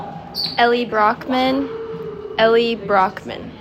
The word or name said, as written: Ellee Brockman